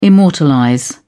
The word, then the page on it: immortalize: British English